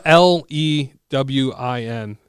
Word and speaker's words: Lewin: L E W I N.